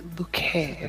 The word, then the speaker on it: Bucket: And it's pronounced bouquet.